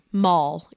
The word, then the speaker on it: Moll: Moll, yeah. – Can you spell that for me? – Moll. Like Molly minus the Y.